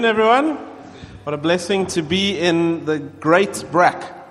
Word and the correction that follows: Groot Brak: As Paul taught me how to say it, yeah, the groot Brak.